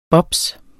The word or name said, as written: bobs